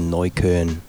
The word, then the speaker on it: Neukölln: I was staying in a neighborhood called Neukölln. Neukölln. – That's how you pronounce it? – Yeah, I got. – You got it down.